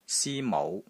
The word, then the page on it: SI1 MOU5: Cantonese